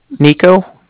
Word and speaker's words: Niko: Nico, spelled N-I-K-O. And then I was calling with the Bank of Cardiff, and Cardiff is spelled C-A-R-D-I-F-F, as in Frank, Frank.